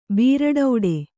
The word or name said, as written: ಬ್ರೆಡ್ಡು